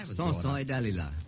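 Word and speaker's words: Samson et Dalila: Sansoy Dalila is the way it's pronounced, all right? – How did you say that? – Sansoy Dalila.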